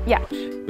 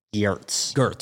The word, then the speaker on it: Giertz: So I started a product design company and I named it Giertz. – Which again is the actual pronunciation of her name, which I would have thought is Giertz.